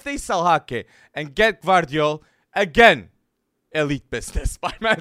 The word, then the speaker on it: Gvardiol: It didn't look good for Upamecano, and Gvardiol was the elite, with Schlotterbeck too, center back that everybody was impressed. So, I said the name right. – you did. You did say it right.